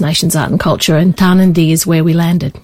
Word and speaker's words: Tarnanthi: I'm saying it wrong too, aren't I? I've been saying Tarnanthi. – That's okay. It's really tricky. And it's important to... So Tarnanthi is the way you would say it.